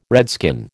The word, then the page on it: redskin: American English